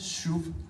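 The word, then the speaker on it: shuv (שוב): Det her, det er en SH-lyd, så har vi en U-vokal, og så egentlig et B, men når der ikke er nogen prik i, så udtales det som et V. Så det er ordet shuv.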